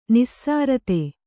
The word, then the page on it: ನಿಸೃತ: nisřta